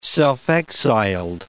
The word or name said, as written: self-exiled